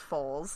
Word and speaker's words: foals: Oh, wait, it's not fowls either. It's foals. Sorry, I said that wrong. F-O-A-L-S. Foals.